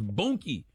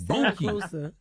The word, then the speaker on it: Boonky: No, it don't rhyme with Dookie. Bookie, and it'd be Bookie if it was Dookie. It's Boonky. – Oh, right. – With an N. Boonky.